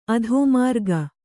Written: ಅಧೋಮಾರ್ಗ

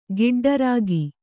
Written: ಗಿಡ್ಡರಾಗಿ

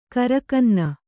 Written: ಗಿರಿಕನ್ನೆ

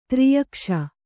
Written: ತ್ರ್ಯಕ್ಷ

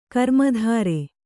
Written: ಗ್ರಾಮದೆರೆ

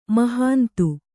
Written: ಮಹಂತೆ

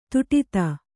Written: ಧಡೂತಿ

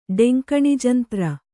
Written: ಡೆಂಕಣಿಜಂತ್ರ

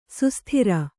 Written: ಸುಸ್ಥಿರ

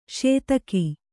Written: ಸದಕ

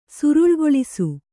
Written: ಸುರುಳ್ಗೊಳಿಸು

